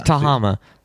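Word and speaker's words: Tehama: In Northern can you pronounce the name of the county right? – No, Tahama. – Where does it say the county? – To Yakahama, Yamazuchi. – Tahama. It's Tahama. There's three vowels.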